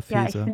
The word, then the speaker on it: Faeser: Und ich dazu will ich auch noch irgendwie sagen, dass ich irgendwie das sehr ähm, echt ekelhaft irgendwie finde, wie Nancy heißt sie Nancy Faeser, spricht man mhm. den Namen so aus? – Ja, ja, Faeser. Ja,